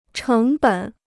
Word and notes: 成本: chéng běn

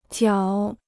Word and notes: 绞: jiǎo

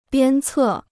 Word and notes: 鞭策: biān cè

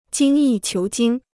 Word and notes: 精益求精: jīng yì qiú jīng

